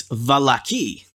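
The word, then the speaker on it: Vallaki: Irina says it's Valaki. Or the emphasis is on the second syllable. – Ah, that's the third syllable.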